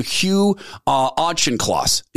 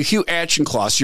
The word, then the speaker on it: Auchincloss: And at the top of this page, you sent this email to Hugh Auchincloss. – Yeah, correct. – Is that how you say his name? – Yeah, that's correct. – You sent this email to Hugh Auchincloss, your principal deputy.